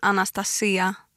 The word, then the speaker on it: Anastasia: Om jag ska på grekiska så säger man Anastasia med betoningen på i-et då.